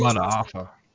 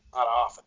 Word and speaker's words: Mataafa: How do you say Mataafa. – Mataafa, thank you.